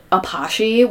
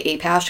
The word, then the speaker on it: Apashe: We specifically went for this, like, really unique EDM artist called Apache. We would always mispronounce it and say Apash because that's how it's spelled. APASHE.